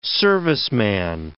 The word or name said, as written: serviceman